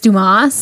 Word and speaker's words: Dumas: Because when you see the word dumbass, mm-hmm. but it's a last name, it's Dumas.